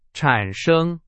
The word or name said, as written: 产生